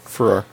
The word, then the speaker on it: Ferrer: I don't know if I'm pronouncing Ferrer. – That right. – Ferrer.